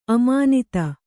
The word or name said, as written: ಅಮಾನಿತ